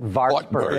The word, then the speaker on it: Wartburg: It sounds better in English if you pronounce it Wartburg.